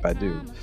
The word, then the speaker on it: Badu: I think I'm saying that right, Badu.